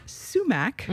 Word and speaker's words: sumac: Somag, which is sumac. – Mm-hmm. Our sumac, yeah. – Yum. – Did I pronounce that right? Because I'm always told that I mispronounce sumac. – No way. Okay. It's perfect.